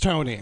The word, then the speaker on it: Tony: I love when a word has an E-I-G-H. Like, my name is Anthony. I'm going to start going by Tony, but I'm going to spell my name T-O-G-N-A. Because I love the way bologna is spelled. It's not Togna, it's Tony.